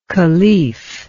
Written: khalif

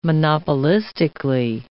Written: monopolistically

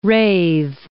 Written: rathe

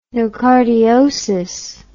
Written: nocardiosis

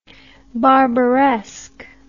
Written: barbaresque

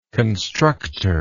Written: constructor

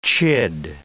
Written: chid

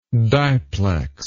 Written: diplex

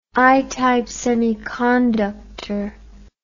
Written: i-type semiconductor

